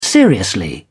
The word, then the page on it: seriously: British English